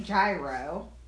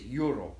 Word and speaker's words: gyro: But it's technically it's euro is how you say it.